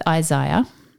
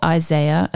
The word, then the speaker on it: Isaiah: So we're going to start with Isaiah, or Isaiah, as I think Americans say.